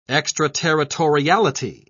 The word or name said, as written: extraterritoriality